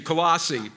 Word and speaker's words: Colossae: Paul was writing to the church in Colossae. Sarah, how do I say that? Thank you, Colossae.